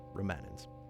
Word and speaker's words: Remanence: It's called Remanence. Like remains or remnants? Or remnants. Yeah, Remanence.